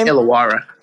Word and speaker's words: Illawarra: Iwaria? – Don't give me the line. – The Hawks, okay? They're the Hawks in the NBA. – Those like Illawarra.